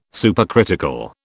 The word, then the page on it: supercritical: British English